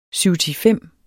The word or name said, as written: syvtifem